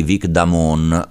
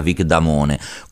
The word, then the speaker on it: Vic Damone: Si tratta di Vic Damone, o come lo pronunciamo noi in Italia, Vic Damone.